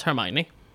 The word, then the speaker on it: Hermione: Och någonting som vi sa att vi inte skulle prata om är hur det uttalas, för det uttalas Hermione.